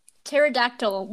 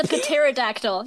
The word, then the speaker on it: pterodactyl: Pterodactyl. What was the point of the P? That's a pterodactyl.